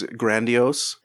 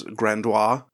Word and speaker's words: grandiose: And some people frankified that and said pronounced it as grandois. – Oh, I could see that. Well, I think when you look at a word, especially one that you think is of the French pronunciation, then you automatically want to put the wa on the end.